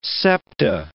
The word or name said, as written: septa